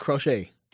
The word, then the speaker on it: Crochet: That's how you say it, Crochet.